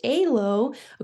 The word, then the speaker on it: EILO: And it pretty much, and then if we tag it on EILO, which is pronounced ALO, according to the conference, that stands for exercise induced laryngeal obstruction.